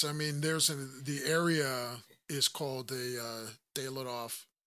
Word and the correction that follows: Dyatlov: Did I say that right? Dayloff? Dyatlov. – Dyatlov.